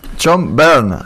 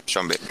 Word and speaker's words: Byrne: John Bern. no es tan difícil, loco. Bern.